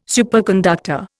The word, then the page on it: superconductor: Indian